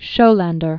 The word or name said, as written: Donald Schollander